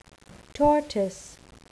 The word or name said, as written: tortoise